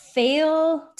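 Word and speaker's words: Féile: Another way that strong Irish heritage is celebrated is during the annual, mispronunciation number three, Feil Tilting Festival. So it's spelt F-E-I-L-E. I'm going to say Feil. Feil Tilting Festival, which is held over 4 days in September.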